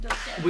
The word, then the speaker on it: Dolcetto: Is that how you say it? – Dolcetto.